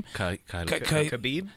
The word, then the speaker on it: Khabib: Khabib.